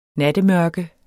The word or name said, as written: nattemørke